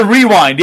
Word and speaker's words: Wre-Wind: Because it's the Wre-Wind. Yeah, it's the Wre-Wind Wrestling Podcast. It's just spelled with W for wrestling.